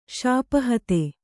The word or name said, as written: ಶಾಪಹತ